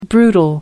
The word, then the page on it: brutal: American English